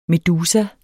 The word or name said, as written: medusa